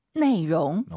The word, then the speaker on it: 内容: 내용을 중국어로 내용. – 오, 상당히 비슷하죠? 내용. 자, 발음하실 때 내용, L 발음 안 돼요. 내용, 롱 말아서 내용.